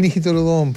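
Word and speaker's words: negitoro don: Nijitorodón. – Ah, el nijitorodón, pues. – ¿Ya qué es? – Muy rico, no tengo idea. Nijitorodón. – Ya, pero cocina. Está muy bien pronunciado.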